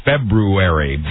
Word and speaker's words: February: It's spelled and correctly pronounced February. – Thank you. – That's hard to say, so everybody just says February, and that's fine.